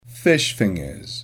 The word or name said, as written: fish fingers